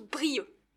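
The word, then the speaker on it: Brieuc: Avec un C à la fin. On écrit Brieux, mais on prononce Brieux.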